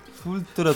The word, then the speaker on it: Full Throttle: Es Full Trot, ¿cómo se pronuncia? Full Trot también en esa época. ¿Cómo se pronuncia, loco? La aventura gráfica de Lucas. – Full Trot. – ¿Cómo se pronuncia? Posta. Full Throttle, algo así, ¿no? – Full Throttle. Full...